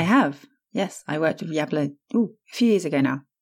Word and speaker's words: Yabla: I don't know how to best pronounce it. Yabla. It's kind of like Kablam.